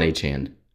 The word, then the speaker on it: Achane: I still don't know how to pronounce his Miami running back's last name. – Devon Achane. – Achane? – Yeah. – He hit with Achane, even though Achane got hurt in the game.